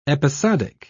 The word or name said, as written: episodic